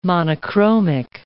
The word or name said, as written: monochromic